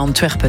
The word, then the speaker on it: Anvers: J'ai bien prononcé Anvers. – Oui, ou alors si vous avez un doute, vous le dites à la flamande. Hein, Antwerp, ça fonctionne aussi.